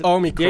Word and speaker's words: Omicron: it's Omicron. The ancient Greeks did. The emphasis is on the first...